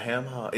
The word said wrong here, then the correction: hock: Is it a ham hog? No. Have I been saying that wrong all my life? – No, it's hawk.